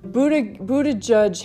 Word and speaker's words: Buttigieg: Buttigieg has a problem with his name, although when you spell it B-U-D-D-H-A hyphen J-U-D-G-E, it stands for integrity and gay rights in politics.